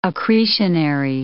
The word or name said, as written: accretionary